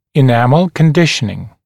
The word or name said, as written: enamel conditioning